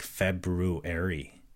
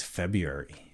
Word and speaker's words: February: By the way, the first R in February is silent. You know what? I didn't know that for the longest time. And I do hear a lot of people, in fact, a couple of my friends that I will not name right now, but they say it like February, but it's February.